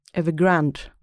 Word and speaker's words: Evergrande: Grand, hur, va? – Evergrande. – Mm. Ja, jag har alltid undrat hur det uttalas.